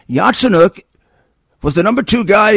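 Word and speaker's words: Yatsenyuk: And it says, get me the document on Artsenuk. Artsenuk was the prime. Now, maybe Hur doesn't know who Artsenuk is. – Yatsenuk. Or something like that. Sorry, I pronounce it Yatsenuk.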